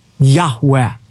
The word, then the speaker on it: Yahweh: In Hebrew, it would be composed of the four Hebrew letters, Yud Heh, Vav Heh, which most Semitic scholars believe is pronounced Abrathi Yahweh.